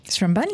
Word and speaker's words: Seremban: Yes, we play our first concert on the 3rd of February in, I don't know if I pronounce it right, in Seremban. – Seremban, yes.